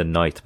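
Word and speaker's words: knight: این کلمات نایت به معنی شوالیه و نایت به معنی شب تلفظ یکسانی دارن و توی سراسر بریتانیا اتوبوس‌های شبانه پس از پایان ساعات کار ناوگان عادی حمل و نقل مشغول ارائه خدمات میشن.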